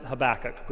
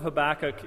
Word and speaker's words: Habakkuk: I just learned this week, I didn't know this, but it's actually pronounced Habakkuk. That's the correct pronunciation for this book. I'm not going to pronounce it correctly. I've always pronounced it Habakkuk growing up. So I'm acknowledging that I'm pronouncing it incorrectly, but I'll probably just kind of default into that pronunciation anyway.